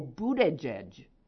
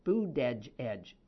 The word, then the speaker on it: Buttigieg: Buddha. Oh, pronounced like Bood. Oh, Buttigieg.